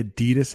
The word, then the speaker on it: Adidas: You might hear the Nike outlet, the Adidas outlet. That's how I pronounce that. That's how a lot of Americans pronounce that name brand.